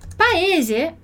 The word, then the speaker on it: paese: Paese é uma palavra que você já pronunciaria dessa forma, não é? De novo, a gente tem o S aqui com som de Z.